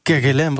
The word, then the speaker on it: Kerguelen: Zeg ik dat goed? – Kerguelen, het is volgens mij Frans. – Oh, Kerguelen. – Dus Kerguelen.